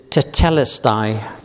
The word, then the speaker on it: tetelestai: If I pronounce it correctly, it is tetelestai. T-E-T-E-L-E-S-T-A-I.